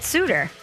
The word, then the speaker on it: Sutter: So and how do you get Suter? – Again, I don't know of any other S-U-T-T-E-R that pronounces it Suter other than Bruce and his family.